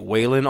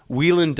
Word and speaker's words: Whelan: We found recently that although we've been pronouncing it Waylon all this time, Whelan does come up as the... more popular Irish pronunciation.